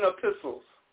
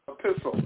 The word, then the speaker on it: epistles: E-P-I-S-T-L-E, and the T is pronounced as an S. A pistol. – E-P-I-S-T. – Okay, E-P-I-S-T-L-E-S, epistles.